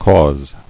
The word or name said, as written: Causable